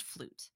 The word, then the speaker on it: fluyt: That's spelled F-L-U-Y-T. It's a Dutch word, but it's pronounced flute.